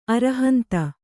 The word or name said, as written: ಅರುಹಂತ